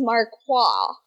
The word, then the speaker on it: Marquess: Is it Marquess or Marquess? – I read somewhere in my research about Sweet Baby Henry Cyril that it was Marquois. – Oh, Marquois. But it's not spelled like O-I. – I know. It's not spelled in like a French way. – It's spelled like Quest.